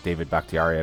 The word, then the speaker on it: David Bakhtiari: Again, the offensive line like was just absolutely terrible for the Packers, and I think they were missing David Bakhtiari.